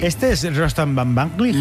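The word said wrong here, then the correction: Batmanglij: Casi bien pronunciado, ¿eh? O sea, tercero de Radio 3 tiene casi. – Van Vanglig. – Batmanglig. – Batmanglig.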